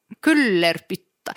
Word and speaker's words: Kyllerpyttaa: K-Y-L-L-E-R-P-Y-T-T-A-A. Kullerpytta.